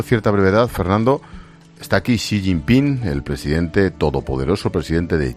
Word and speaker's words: Xi Jinping: Que no es cualquiera el que viene, es Xi Jinping, que en fin, que bien lo pronuncias, por cierto, Xi Jinping.